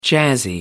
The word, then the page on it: jazzy: American English